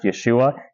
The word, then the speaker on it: Yeshua: Interesting side note, by the way, Jesus' name in Hebrew is probably pronounced Yeshua.